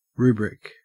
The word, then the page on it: rubric: Australian English